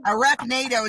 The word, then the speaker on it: Arachnado: Arachnado. – It's like short. It's like Sharknado, but they're doing it with a year ago.